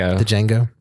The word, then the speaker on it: Django: Django. The D is silent.